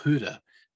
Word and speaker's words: Gouda: The plan was we were playing a game against, I believe it was Gouda, was how we always said it. I believe it's pronounced Gouda in Dutch.